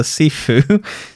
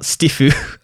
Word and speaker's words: Sifu: Not the name of the guy, the Sifu, not Stifu. – I thought I saw T in there, right? Sifu. – Cool. Sifu.